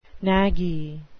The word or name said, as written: knaggy